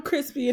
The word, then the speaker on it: Chrispy: Oh, and I'm Crispy, and that's spelled C-H-R-I-S-P-Y, also known as Lando Calrissian, also known as mac and cheese, also known as moist turkey.